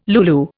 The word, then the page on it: lulu: Indian